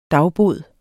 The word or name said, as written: dagbod